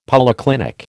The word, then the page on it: polyclinic: American English